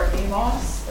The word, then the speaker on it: Amos: We'll be reading from the book of Amos, or Amos, as the Brits say, chapters 1 through 2, verse 6.